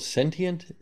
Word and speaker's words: Cntient: So looking at your website, you have, so is it called Sentient? C-N-T-I-E-N-T? Is that how it's pronounced? – Yes, that's correct.